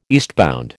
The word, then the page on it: eastbound: American English